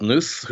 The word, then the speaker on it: Knuth: Knuth, who wrote I think I'm pronouncing his name correctly, K-N-U-T-H.